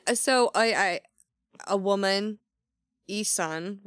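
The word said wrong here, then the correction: Essun: right? – Aesun, sorry. – No, it's fine. That I only know that because that's how the narrator pronounced it.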